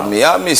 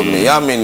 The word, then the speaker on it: Miami: Hata Miami sio Miami, ni Miami.